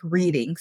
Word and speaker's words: Reading: I think it's in Reading, Pennsylvania, but to be fair, it is spelled like reading, so it could be either way.